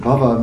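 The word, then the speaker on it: bhāva: The Sanskrit word bhava means existence, and then bhava with a long A is a state of existence.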